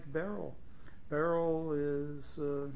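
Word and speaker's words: Beryl (בערל): The way I just pronounced it, you would spell it Beis, Ayin, Reish, Lamed. Other people I hear tell spell it Beryl, because they hear the yud as a separate sound, beril, instead of beryl. It depends where you put the accent, at the beginning or at the end.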